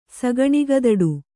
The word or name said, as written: ಸಗಣಿಗದಡು